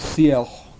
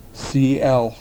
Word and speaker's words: Sealth: Chief Seattle, whose real name was Sielch, which is most closely approximated in spelling as S-E-A-L-T-H, but the T-H is not pronounced behind the teeth, but rather out of the sides of the mouth, Sielch, was a CM.